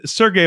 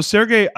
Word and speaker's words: Sergey: Sergey, am I pronouncing your name properly? – Yes. – So Sergey,